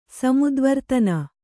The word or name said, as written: ಸಮುದ್ವರ್ತನ